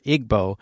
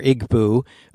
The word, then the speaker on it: Igbo: called Igbo, or Igbo, depending on how you want to pronounce it, and it's spoken, Steve, by 27 million people in Nigeria.